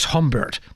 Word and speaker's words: Gombert: Before we continue our Mass music with the Alleluias, here's a lovely motet by the Netherlandish composer Nicholas Humbert, that's spelled G-O-M-B-E-R-T.